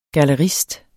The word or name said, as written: gallerist